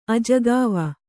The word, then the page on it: ಅಜಕವ: Kannada